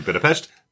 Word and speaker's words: Budapest: But I do love the gag of her pronouncing Budapest correctly and everybody else correcting her constantly. Budapest.